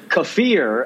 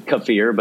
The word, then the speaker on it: kefir: And also, before we go, I have to apologize for the we were pronouncing Kafir apparently totally wrong. – You are such an asshole. – Kafir. it's delicious is kefir, but it's not kefir, I guess. I like kefir better, but kefir. Thank you for correcting us on that.